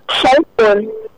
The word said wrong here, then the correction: xylophone: No, let me help you with the word. The word is pronounced as xylophone. – Okay. – Yeah, so correct your friends from today. It's xylophone. It has a z sound there.